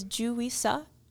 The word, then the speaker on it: Juri Seo: Uh, it's spelled J U R I and then S E O. And in her bio on her website and everything, she does have at the bottom the uh, North American pronunciation help, which That's says J E W R I S U H.